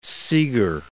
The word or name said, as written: Seeger